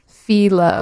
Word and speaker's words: Fyllo: And tell me the name again. – Philo. – Like Philo Dough? – Yeah. – But it's spelled... – F-Y-L-L-O.